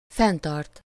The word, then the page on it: garder: [ɡaʁ.de]; France (Paris)